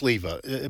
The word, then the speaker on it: Sliwa: I don't know if Curtis, I think it's Sliva, but it's spelled S-L-I-W-A, but I think it's pronounced with a V.